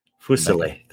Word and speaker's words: Fuselier: Fuselier. Three syllables. – Fuselier.